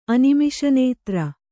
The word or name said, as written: ಅನಿಮಿಷನೇತ್ರ